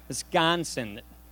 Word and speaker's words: Wisconsin: But the pronunciation, we say Wisconsin through the nose. Wisconsin.